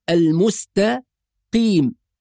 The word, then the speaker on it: المستقيم: لا نجعل القاف المفخمة تؤثر على السين والتاء المرققة. ففي البداية نفصل بين السين والتاء وبين القاف. تدريب، نقول المستقيم، مع التدريب نربط الكلمة كلها مرة واحدة فنقول المستقيم، وهذا النطق الصحيح.